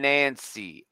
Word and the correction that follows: Anansi: Anansi. – It's Anansi, very hard for the, me to pronounce the that.